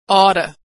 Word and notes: ought: American English

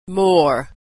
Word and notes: more: American English

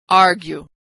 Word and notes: argue: g

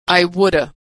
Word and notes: I woulda: American English

